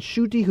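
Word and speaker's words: Schutte: And we're also very excited to have Dan Shute. Yes, the Dan Shute. Some people say his name is Shuti. Dan Shuti, who's composed many of our most loved songs for Mass.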